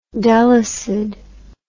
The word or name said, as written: Delysid